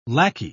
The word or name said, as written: lackey